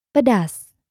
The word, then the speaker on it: pedas: Sometimes the character E sounds like U. – Can you give us an example? – Sure. Pedas. Pedas. This means spicy hot. This is spelled P-E-D-A-S. Pedas. – Did you notice that this time the E is pronounced as U?